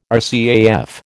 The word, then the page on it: RCAF: American English